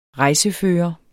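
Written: REJSEFØRERE

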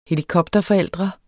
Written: helikopterforældre

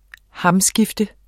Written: hamskifte

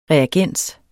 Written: reagens

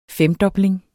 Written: femdobling

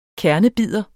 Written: KERNEBIDER

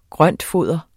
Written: grøntfoder

